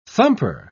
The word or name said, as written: thumper